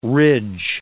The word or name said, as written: ridge